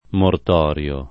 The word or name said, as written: mortorio